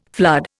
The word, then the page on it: flood: Indian